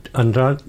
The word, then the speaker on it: Andrade: And the Andrade, you don't pronounce the, Andrade. – Andrade? – Yeah. – The D sounds a little bit less, it sounds quiet.